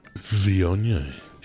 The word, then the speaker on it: Viognier: Viognier.